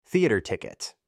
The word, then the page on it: theater group: American English